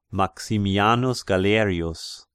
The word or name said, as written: Maximianus Galerius